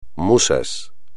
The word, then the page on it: musas: espagnol